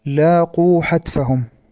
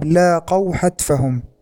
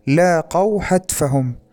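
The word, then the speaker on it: لاقَوا: العبارة السابعة: لاقوا حتفهم، هكذا نطقها المذيع بضم القاف، وهذا نفس الخطأ في عبارة أردوه قتيلا، حيث استعمل المذيع صيغة الأمر بدلا من صيغة الماضي، والصواب لاقوا حتفهم، لاقوا حتفهم. فالفرق بين الصيغتين في حركة واحدة، الماضي بفتح القاف لاقوا، والأمر بضمها لاقوا.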